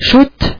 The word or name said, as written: štěrk